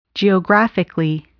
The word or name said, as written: geographically